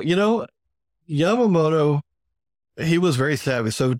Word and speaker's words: Yamamoto: Am I saying that right? Yamamoto? Okay. This guy, he's considered like the guy behind the attack on Pearl Harbor, the architect.